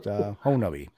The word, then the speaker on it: Honobia: So now, the siege of Honabi, as they call it, the siege of Honabi. It looks like Honobia, but it's actually pronounced Honabi.